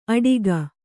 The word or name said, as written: ಅಟಿಗ